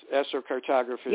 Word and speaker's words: astrocartography: So again, you not only do the you do the astrocartography. Is that the way it's pronounced? – Yes, astrocartography.